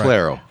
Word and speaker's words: Claro: Claro.